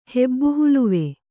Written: ಹೆಬ್ಬುಹುಲುವೆ

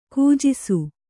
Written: ಕೂಜಿಸು

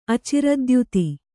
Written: ಅಚಿರದ್ಯುತಿ

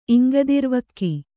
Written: ಇಂಗದಿರ್ವಕ್ಕಿ

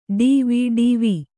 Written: ಡಿವಿಡಿವಿ

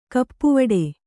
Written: ಕಪ್ಪುವಡೆ

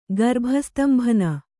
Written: ಗರ್ಭಸ್ತಂಭನ

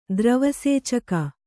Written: ದ್ರವಸೇಚಕ

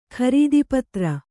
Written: ಖರೀದಿಪತ್ರ

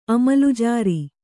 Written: ಅಮಲ್ಜಾರಿ

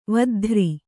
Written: ವದ್ಧ್ರಿ

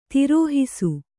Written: ತಿರುಹಿಸು

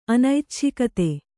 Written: ಅನೈಚ್ಛಿಕತೆ

